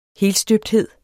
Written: helstøbthed

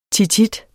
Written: tit

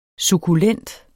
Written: sukkulent

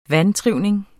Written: vantrivning

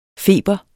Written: Feberen

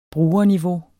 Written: brugerniveau

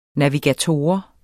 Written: NAVIGATOR

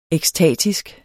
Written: ekstatisk

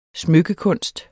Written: smykkekunst

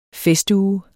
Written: FESTUGE

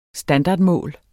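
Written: standardmål

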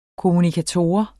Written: kommunikator

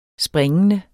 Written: springende

